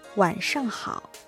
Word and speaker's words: 晚上好: はい、こんばんはは晚上好、晚上好。 – 今度は、晩という字ですね、夜という意味の晩、それに上に好きと書いて晚上好、これがこんばんはですね。